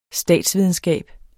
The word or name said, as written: statsvidenskab